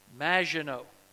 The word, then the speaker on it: Maginot: Maginot. That's how you pronounce it. It's French.